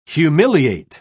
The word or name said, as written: humiliate